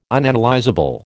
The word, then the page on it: Unanalysable: American English